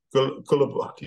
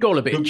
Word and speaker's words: Golubic: Elise Cornet up against Victoria Golubic. You should pronounce the name for me. I can't remember writing it. – Golubic. – Golubic, there you go.